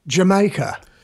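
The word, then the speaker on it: D'yer Mak'er: would you say the name properly for me? – Jamaica. – And that is, the reason we say that way is? – Is because it's a play on words.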